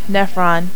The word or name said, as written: nephron